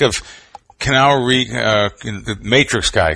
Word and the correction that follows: Keanu Reeves: Am I saying his name right? Kenal Reeves? – Keanu Reeves. – Keanu. Oh, Keanu.